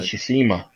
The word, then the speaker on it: Tsushima: Ghost of Tsushima, whatever. – Ghost of Tsushima.